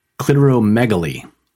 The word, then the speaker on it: clitoromegaly: Clitoromegaly.